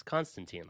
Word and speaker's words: Constantine: Just reading through some of the comments, the name that I butchered is Constantine, like John Constantine from the comics.